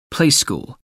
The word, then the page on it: playschool: American English